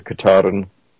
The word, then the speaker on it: Katadyn: I like the Katahdin. That's made in Europe. I believe it's spelled K-A-T-A-D-Y-N.